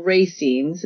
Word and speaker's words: racemes: And her violet purple racemes, is that how you pronounce it? Racemes? – Yep.